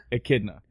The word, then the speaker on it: echidna: So Knuckles is a, what's the proper pronunciation? Echidna? – Echidna.